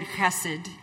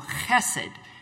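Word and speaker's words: chesed (חסד): Now, the CH at the beginning of the word chesed is pronounced kind of like the CH that we hear in Bach. And so if you put that at the beginning of the word, and then blessed is chesed is pronounced a little bit like blessed, so chesed.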